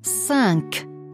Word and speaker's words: cinq: C vor I und E wird als scharfes S ausgesprochen. – Cinq, cinéma, commencer.